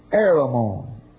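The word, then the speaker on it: Heremon: This eastern princess was married to King Heramon, usually with a silent H, Heramon,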